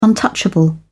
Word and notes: untouchable: British English

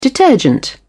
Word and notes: detergent: British English

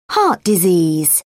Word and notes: heart disease: British English

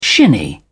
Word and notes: shinny: British English

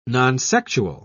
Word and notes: nonsexual: British English

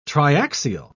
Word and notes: triaxial: British English

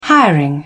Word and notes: hiring: British English

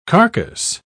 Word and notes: carcase: British English

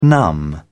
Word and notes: numb: British English

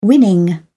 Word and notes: winning: British English

